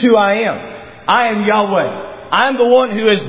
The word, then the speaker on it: Yahweh: All right, I'm going to use Yahweh as my pronunciation.